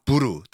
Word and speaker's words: bur: 그 요새를 B-U-R, bur 또는 buru 여러 가지 발음이 있는데.